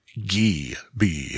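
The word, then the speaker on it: Gee Bee: So yeah, that first game, which was kind of a combination pinball / breakout game, was actually pronounced Gee B, hard G, not soft G.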